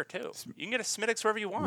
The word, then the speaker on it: Smithwick's: There was a Smittix. It's spelled like Smithwick's or something, but it's just they call it Smittix.